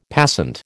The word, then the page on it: passant: American English